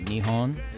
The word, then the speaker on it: Nihon: I think they say Nihon is the way you say it.